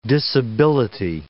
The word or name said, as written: disability